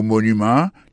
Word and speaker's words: monument: Pour mot monument, il tombé sur premier syllabe, là, tout.